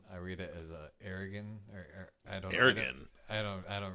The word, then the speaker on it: Aragorn: Aragorn's quest. Is it Aragorn? Am I saying that right? – Aragorn? – Aragorn.